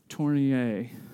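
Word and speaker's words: Tournier: I don't think you pronounce the R on the end. Tornier. There you go.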